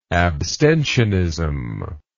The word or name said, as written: abstentionism